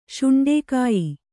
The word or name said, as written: ಶೌಂಡಿಕ